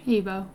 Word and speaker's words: Igbo: Igbo community? – Igbo. I think the G is silent.